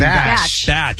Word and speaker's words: Bach: Now, what is out now that I know you're you got a big part in is that you produced for King Bach, right? – Yeah, King Bach. – Batch. – He's so funny. – That's how you say that? – Yeah. – I've been saying it wrong this entire time.